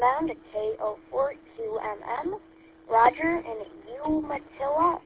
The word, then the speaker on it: Umatilla: And yeah, you said that really good, Umatilla, Florida.